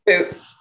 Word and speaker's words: Bout: And we're going to give them Victor Bout, who is the boot. – It's spelled Bout.